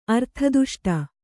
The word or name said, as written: ಅರ್ಥದುಷ್ಟ